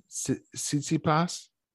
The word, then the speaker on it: Tsitsipas: Tsitsipas? – No way he said his name right.